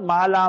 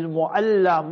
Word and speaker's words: Mu'alla: See, even that place we pronounce wrong. Not Mala, Mala. Mala means to be crazy in Afrikaans, you know. It's Mu'alla.